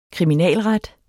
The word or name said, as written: kriminalret